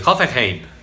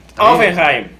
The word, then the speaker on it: Hoffenheim: El Olympique de Lyon, el Shakhtar Donetsk y el, ¿cómo se dice? Hoffenheim, el alemán. Hoffenheim.